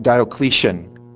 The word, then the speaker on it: Diocletian: And so we get to the emperor, and I want to say it right, Diocletian.